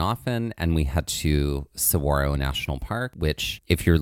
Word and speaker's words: Saguaro: there is a G, but that G is silent. – It's like a W. – It's like a W. Mm-hmm. Saguaro is how it's pronounced.